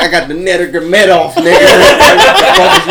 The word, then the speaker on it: Nurmagomedov: Conor McGregor is fucking back fighting Khabib Nurmagomedov.